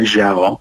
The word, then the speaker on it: Zhao: Chloe Zhao, ja przepraszam, że tak, bo on chyba się wymawia Zhao,